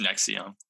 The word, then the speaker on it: NXIVM: It's NXIVM, but is it NXIVM? – It's NXIVM. – Oh, I said NXIVM. – Yeah, you pronounce the V.